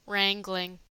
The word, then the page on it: wrangling: American English